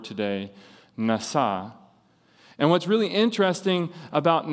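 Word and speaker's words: nasa (נשא): the word nasa.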